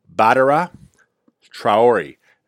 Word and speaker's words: Badara Traore: Offensive tackle Badara Trahoe. I'm butchering that name. I know I am. Badara Traore, knee injury.